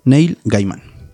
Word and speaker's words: Neil Gaiman: Literalmente, así como lo escuchaste, así se escribe. Neil Gaiman.